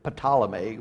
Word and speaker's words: Ptolemy: It has a P in front of it, Ptolemy, but we don't pronounce the P.